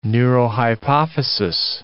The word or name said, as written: neurohypophysis